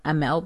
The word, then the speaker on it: Amell: I don't think anyone's ever called them Amell before. – How do you say it? – Amell. – Amell? Okay, that makes sense.